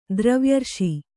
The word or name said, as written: ದ್ರವ್ಯರ್ಷಿ